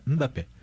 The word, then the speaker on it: Mbappé: Então é Mbappé. As pessoas falam Mbappé, Mbappé. – Eu falava Mbappé. – O Mbappé, Mbappé. – Mbappé.